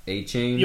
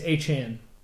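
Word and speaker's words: Achane: But A-Chain, is that how you pronounce it? – Well, okay, so yes, but apparently this week he's decided that it's not A-Chain, it's A-Chan. – A-Chan. – Yeah, Devon A-Chan.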